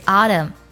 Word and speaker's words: autumn: 秋天的英语呢是 autumn，它的拼写最后呢是 m n 结尾，但是 n 呢是不发音的。如果你看音标呢，它的最后一个音素呢就是嗯这个音啦，autumn。